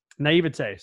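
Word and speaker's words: naïveté: Beautiful, limitless naivety. naivete. Sorry, I keep saying it wrong.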